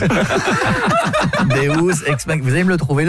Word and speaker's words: deus ex machina: Deus ex machina. – Ah, parce que deux, j'aurais dit que c'était deux ex que t'appelles machine. Tu vois, ça se prononce deus ex machina.